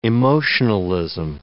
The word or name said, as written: emotionalism